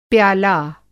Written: प्याला

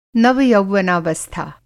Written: नवयौवनावस्था

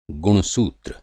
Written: गुणसूत्र

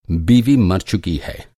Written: बीवी

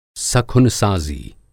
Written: सखुनसाज़ी

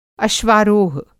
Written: अश्वारोह

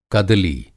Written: कदली

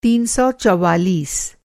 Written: तीन सौ चौवालीस

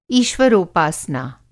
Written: ईश्वरोपासना

